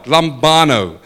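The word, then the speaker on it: Lambano (λαμβάνω): The word there for receive in Greek is the word lambano. And I hope I say it right. Lambano. But it... Lambano. Okay, we've got it.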